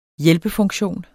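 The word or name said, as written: hjælpefunktion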